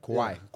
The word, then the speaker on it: Kauai: Or was it Kauai? – Kauai. – I know it's Kauai, from Kauai. – Sorry, I don't pronounce the U, my bad. Kauai.